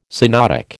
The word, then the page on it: synodic: American English